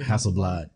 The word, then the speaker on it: Hasselblad: Is that how you say it? – Hasselblad. – Blad.